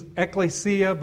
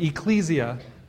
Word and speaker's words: ekklesia: the Greek pronunciation's ekklesia, but, you know, they live in the mountains, so they can pronounce it however they want, right? So they pronounce it Ecclesia Baptist Church.